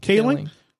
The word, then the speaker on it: Kaling: Kaling.